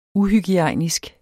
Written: uhygiejnisk

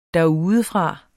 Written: derudefra

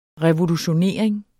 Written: revolutionering

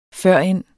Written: førend

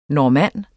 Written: normanner

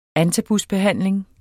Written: antabusbehandling

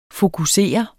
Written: fokusere

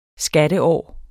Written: skatteår

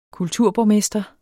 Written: kulturborgmester

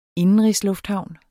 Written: indenrigslufthavn

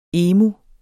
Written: emu

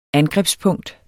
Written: angrebspunkt

